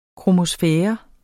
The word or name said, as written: kromosfære